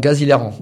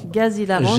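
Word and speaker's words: Gars z'hilarants: Alors, Gazilaran. – Gazilaran, ça s'écrit... G-A-R-S, Z apostrophe, Hilaran, H-I-L-A-R-A-N-T-S. Alors, Z apostrophe, parce que les gens ne faisaient pas la liaison.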